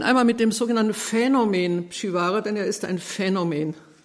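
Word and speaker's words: Przywara: Geboren 1889 in Katowice, Oberschlesien, heute Polen, und der Vater war Pole, daher der Name, ähm, richtig ausgesprochen Pschivara. Es gibt jede Menge Aussprachen, die sind nicht gut.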